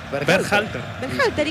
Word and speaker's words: Berhalter: ¿Cómo es el apellido? – ¿Cómo? – Dígalo usted. – Berhalter. – ¿Y qué dije yo? – No, impecable.